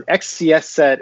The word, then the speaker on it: XCSSET: Um, XCS set is how I like to pronounce it.